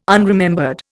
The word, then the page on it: unremembered: Indian